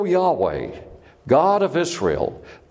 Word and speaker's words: Yahweh: And God's proper name is Yahweh. Yahweh. It is the most biblically consistent, Hebrew consistent way to pronounce the four letters.